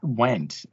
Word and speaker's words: Wendt: Wendt. – I said it right? George Wendt? – Yeah, but I'm just trying to think.